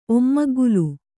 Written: ಒಮ್ಮಗ್ಗುಲು

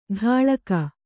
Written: ಢಾಳಕ

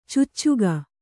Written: ಚೂಚುಕ

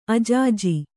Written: ಅಜಾಜಿ

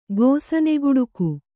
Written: ಗೋಸನೆಗುಡುಕು

